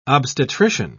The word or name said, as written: obstetrician